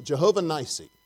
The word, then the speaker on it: Jehovah Nissi: That's how you pronounce it, Jehovah Nissi.